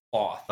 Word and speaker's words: Thoth: So, do you pronounce that Toth? – It's Toth. – Do you say Thoth? – Yeah, that's how the audiobook says it too. Thoth.